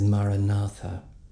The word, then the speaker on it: Maranatha: And the one that's recommended is Maranatha, which means come, Lord Jesus. It's an ancient Aramaic word. And we break it into its four syllables. Ma-ra-na-tha.